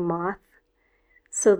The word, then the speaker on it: moths: There's more than one kid sleeping in a hammock like a moth, so the plural of moth, remember, is pronounced moths.